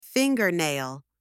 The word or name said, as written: fingernail